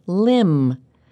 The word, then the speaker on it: limb: And in the next line, they use the word limb. And this one looks like it has a consonant cluster, but it doesn't. The B is silent.